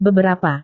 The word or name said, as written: beberapa